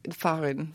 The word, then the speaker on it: fahren: Fahren. – Fahren, right? – Yes. Fahren. But the H is silent, so it's fahren.